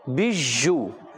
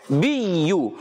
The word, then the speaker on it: زرابی: بعض بچے اور بڑے اس کو پڑھتے ہیں بیجو، یہ غلط ہے، اس کا تلفظ ہوتا ہے بیو،